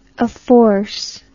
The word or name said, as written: afforcement